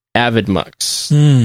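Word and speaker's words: Avidemux: The pronunciation of AvidMux, which is how you pronounce it, I believe. I have taken it to be two words appended, which are AVI, as in AVI video files, and DMux, as short for D multiplex.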